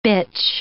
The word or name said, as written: bitch